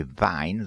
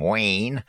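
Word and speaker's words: Wein: If I do the correct pronunciation, it would be Vine, though I suppose the Americans would call him Wayne.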